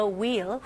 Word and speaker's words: wheel: Rotate the wheel. wheel 아니에요. H는 묵음입니다.